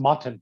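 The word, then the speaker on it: Morten: Well, as you can see, my name is spelled Morton, but it's pronounced Motten. That's a Danish pronunciation.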